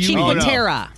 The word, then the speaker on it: Cinque Terre: I don't know. C-I-N-Q-U-E. – Cinque Terre. – Cinque Terre. – Is that what it's... No, is that... – Yeah, C-I-N-Q-U-E-T-R... Yeah, Cinque Terre, yes.